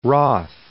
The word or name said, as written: Roth